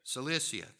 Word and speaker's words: Cilicia: I can't say it correctly. Yeah, say it for me, somebody. Cilicia, thank you. I don't know why I was struggling with that. I was trying to add a bunch of other syllables, which maybe that's a southern thing.